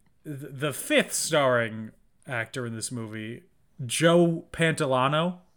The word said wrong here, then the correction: Pantoliano: Pantoliano is actually how I pronounce it.